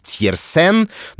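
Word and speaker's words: Tiersen: Речь, конечно, о фильме «Амели» Жанна Пьера Жене и о бессмертном саундтреке к этому фильму авторства Яна Тирсона. Честно признаюсь, я всегда его фамилию произносил именно так. Иногда еще можно встретить такой более франкофонный вариант Тирсен,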